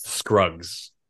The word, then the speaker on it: Scruggs: Am I saying it correctly? Shrugs? – Scruggs. – Scruggs. Damn it.